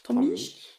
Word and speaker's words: Tom Misch: by Loyal Kana, and is it Tom Misch? Is that how you pronounce his name? – Tom Misch.